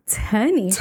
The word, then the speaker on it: Tarnisha: It's Tarnisha. – Tarnisha. – The R is silent and it's there to trip people up.